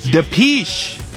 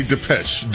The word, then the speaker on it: Depeche: Depeche. Depeche, like capiche, Bo. – Like capiche, and I say Depeche.